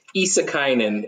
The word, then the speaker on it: Issakainen: But Issakainen is, uh, uh, I mean, you guys were as close as anyone.